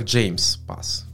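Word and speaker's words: JMESPath: Произносится оно как James Pass. То есть я бы сказал JMS, но James Pass.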